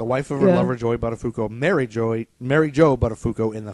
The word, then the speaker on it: Buttafuoco: Pronounced Buttafuoco. – Buttafuoco. – Buttafuoco, sorry. – Not Buttafuoco.